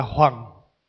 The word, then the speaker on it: hoằng: Tiếng Việt mình đọc là hoàng, Đúng cái âm mà Hán đọc là hoành.